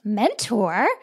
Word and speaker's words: MNTR: David is also co-founder of MNTR, pronounced Mentor, a training company providing professional development resources to emerging executives so they can grow their career.